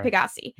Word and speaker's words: Pegasi: Pegasus, probably. No, it's P-A-G-A-S-I. – Okay. – Pegasi.